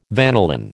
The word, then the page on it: vanillin: American English